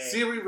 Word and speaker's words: Saoirse: That's not how you say her name. – Saoirse Ronan. – Shay No More Ronan.